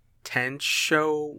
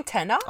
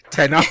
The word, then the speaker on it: Tenoch: But I think it's pronounced Tencho Huerta. – I don't know about Tencho. I was saying Tenoch. – I was thinking also Tenoch. – Okay. Tenoch.